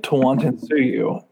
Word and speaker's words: Tawantinsuyu: So I think it's I'm calling it Tawantinsuyu, but um, I've also uh, you know, I don't think that's probably correct.